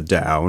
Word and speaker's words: Tao: So it's Tao, T-A-O, pronounced Tao.